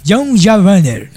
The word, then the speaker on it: Jean Valjean: Jean Valjean.